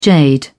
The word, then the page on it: jade: British English